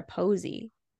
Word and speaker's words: poesy: Is it poesy or posy? – It could be posy. – Posy. I don't know. It's P-O-E-S-Y. And I think it's supposed to be like poem.